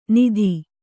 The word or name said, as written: ನಿಧಿ